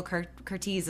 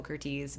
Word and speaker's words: Curtiz: And I think it's really funny because I know his name is Michael Curtiz, but his biographer at TCM Fest was calling him Michael Curtiz because he says that's how it should be pronounced. Because Michael Curtiz made up his name. He's Hungarian, and that's like his Americanized name. And that's how he would say it. But he's like, but based on like what it was, I guess, in Hungary and what it should be Curtiz.